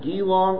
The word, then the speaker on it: Geelong: Quote, in the year 2000, Victory Faith Center was formed in Geelong, Australia.